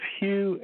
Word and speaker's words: Hugh: My name is Hugh, H-U-G-H. The last name, Charlson, is spelled T like Tom, R-A-U-L-S-E-N like Nancy.